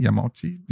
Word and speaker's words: Yamauchi: Ähm, Hirochi Yamuchi, oh, ich hoffe, ich habe es richtig, Yamushi, Yamauchi, ja.